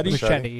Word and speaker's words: Muschietti: Muschietti? Muschietti, yeah.